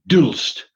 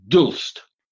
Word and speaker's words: d'Hulst: He has a difficult to pronounce name, Maurice Dulst, D'HULST, Dulst.